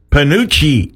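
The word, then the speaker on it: Penuche: It's National, I got to pronounce this right, Panucci Day. That's how they pronounce it in Italian.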